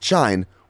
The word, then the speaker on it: Shein: And a California federal court just hit the clothing company Shein, sometimes pronounced Shine, with a RICO lawsuit.